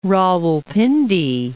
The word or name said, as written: Rawalpindi